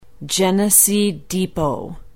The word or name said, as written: Genesee Depot